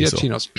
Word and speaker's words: Giacchino: Vi säger Giacchinos.